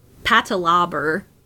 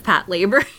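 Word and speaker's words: Patlabor: For years, I thought it was patalabber, instead of Pat Labor.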